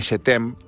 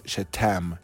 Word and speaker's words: Yetem: Un día voy a hablar acá sobre el creador de la fábrica de juegos Jetem, que se escribe Y-E-T-E-M, Jetem, pero es Jetem, ¿entendés?